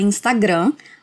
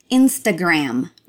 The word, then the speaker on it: Instagram: Em português a gente fala Instagram, mas a pronúncia em inglês é Instagram.